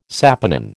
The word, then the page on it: saponin: American English